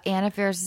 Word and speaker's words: Anna Faris: Our ridiculous podcast, On Affairs Is Unqualified. Thank you so much, Chris. – I think it's pronounced Anna. – Oh, is it? – Yeah. – Fuck. Anna Faris is Unqualified.